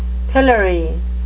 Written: pillory

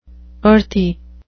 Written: earthy

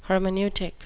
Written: hermeneutic

